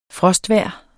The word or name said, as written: frostvejr